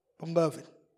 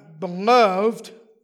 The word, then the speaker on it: beloved: I said, Therefore, my beloved, and then the last word, it's spelled the same way. I, I said, Beloved. It's the difference between an adjective and a noun.